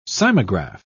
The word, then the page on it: cymograph: British English